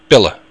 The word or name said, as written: bile